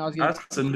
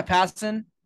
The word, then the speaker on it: Passan: I don't know how you guys say his name. – Passan, dude. – Yeah, Passan.